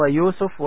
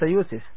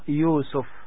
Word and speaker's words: يوسف: النقطة الثانية، وأنا أقصد النطق أيضا، النطق السليم هو يوسف وليس يوسف، فإن كنتم تريدون أن تنطقوا الاسم نطقا سليما فهو يوسف.